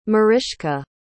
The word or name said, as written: Mariska